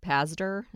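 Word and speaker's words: Pazdur: His name is Rick Pazder.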